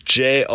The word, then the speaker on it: Jonny: However, the trademark for here's Jonny is all in my hands, because how I pronounce, or how I spell my name, I should say, is Jonny, opposed to the common Johnny.